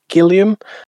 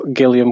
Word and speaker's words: Gilliam: There was a bit of love for the fullbacks, Reggie Gilliam, I want to say. I'm going with Gilliam because it's spelled like William with a G. So I'll go with Gilliam.